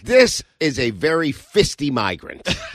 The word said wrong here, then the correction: feisty: I mispronounced feisty. I meant to say feisty.